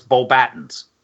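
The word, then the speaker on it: Beauxbatons: There is a school in France, Beaubatons. – Yes. – It's spelled Beaubaxtons, but the X is silent when you pronounce, so it's Beaubatons.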